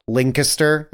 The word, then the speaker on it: Lancaster: He's from Lancaster, Pennsylvania, and Jackie immediately jumps on me, and she's like, if you go to Pennsylvania or anywhere near Philly and say it like that, they'll kill you. It's Lancaster.